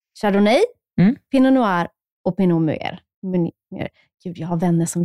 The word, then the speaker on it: Pinot Meunier: Och sen har vi Pinot Meunier.